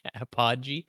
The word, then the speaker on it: Apogee: I think that's actually Apogee. I like it. Someone's been pronouncing their name wrong for like 25 years.